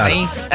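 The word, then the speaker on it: Anaïs: De hecho es Ané, Ané, pero acá en Venezuela era Anaís, Anaís. Pero la pronunciación del perfume francés es Ané.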